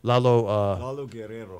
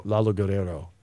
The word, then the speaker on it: Lalo Guerrero: And La Guerra. How'd you pronounce his name again? I keep mispronouncing his name. – Lalo Guerrero. – Lalo Guerrero.